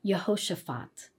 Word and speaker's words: Jehoshaphat: Another example is Jehoshaphat, pronounced in Hebrew as Yehoshaphat.